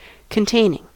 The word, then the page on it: containing: American English